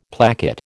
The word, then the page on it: placket: American English